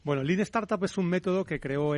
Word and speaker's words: Lean Startup: Lean Startup, sí.